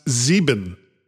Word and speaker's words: sieben: He was reminding us that 7 is pronounced Sieben in German.